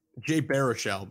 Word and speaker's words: Jay Baruchel: Jay Baruchel, by the way.